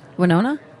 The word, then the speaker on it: Winona: And what about Winono or no? What Winono? – Winona. – Winona.